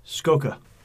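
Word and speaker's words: Scocca: The editor is a former Gawker writer that I don't know how to pronounce his last name because it's S-C-O-C-C-A. – Skoka.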